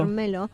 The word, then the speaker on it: Vermelho: Vermelho. – Vermelho.